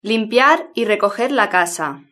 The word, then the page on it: limpiar y recoger la casa: European Spanish